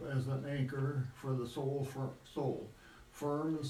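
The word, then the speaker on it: anchor (ἄγκυρα): The Greek word for anchor is anchor, spelled, well, changing it to English letters, A-G-K-Y-R, but pronounced anchor. Because the G, when it comes before a certain letter, changes to an N sound in Greek. So it's anchor, A-G-K-Y-R.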